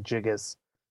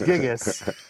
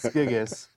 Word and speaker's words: Gigas: I think you were saying like Gigas, but it's Gigas, Codex Gigas.